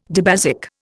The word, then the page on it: dibasic: Indian